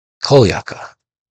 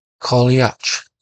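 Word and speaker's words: Cailleach: Kolyaka in Scottish Gaelic, and often Kolyach in Irish pronunciation, is not only a name for a mythic figure, it is also an ordinary word.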